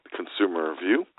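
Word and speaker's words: ConsumerVue: Feel free also to follow me over at Twitter at Consumer View. That's C-O-N-S-U-M-E-R-V-U, silent E. That's C-O-N-S-U-M-E-R-V-U-E.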